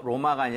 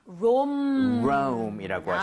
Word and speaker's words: Rome: R-O-M-A가 아니라 영어, 그죠? 영어식 표기는 R-O-M-E고 발음도 심지어 로마가 아니라 Rome이라고 하시면 되겠습니다.